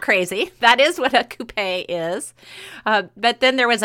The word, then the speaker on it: coupé: First of all, I found out I was pronouncing it incorrectly. So it is coupé. – Oh. – It comes from the French.